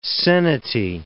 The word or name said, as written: seniti